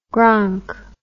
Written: gronk

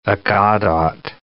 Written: Aggadot

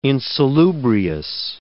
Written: insalubrious

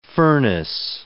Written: furnace

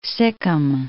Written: Sikkim

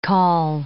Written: call

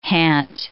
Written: hant

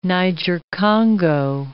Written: Niger-Congo

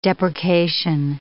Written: deprecation

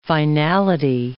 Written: finality

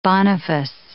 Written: Boniface

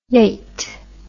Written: yite

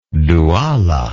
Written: Duala

